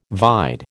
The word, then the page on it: vide: American English